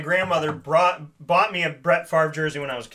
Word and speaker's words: Favre: And I said, Mom, that's how you spell Favre. – Yeah. F A V R E. Brett Favre. Well, this, folks, is how you say it.